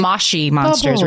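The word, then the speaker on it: Moshi Monsters: Oh, those mushy monsters. They're actually moshy monsters. We're supposed to, we should know better to say it correctly.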